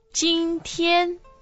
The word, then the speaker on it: 今天: So how do you say today? – 今天 Okay, good. So one more time, today and this year.